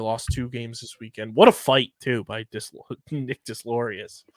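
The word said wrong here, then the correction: Deslauriers: That was an insane fight. – Delorier, yeah. I'm sorry. I mispronounced that.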